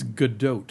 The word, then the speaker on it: Gadot: I'm hearing now it's Gadot. – Gadot. – Because she's Israeli and not French, and they pronounce the T, is what I'm being told.